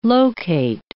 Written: locate

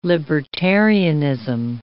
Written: libertarianism

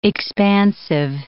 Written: expansive

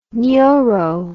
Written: nyoro

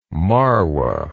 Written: Marwa

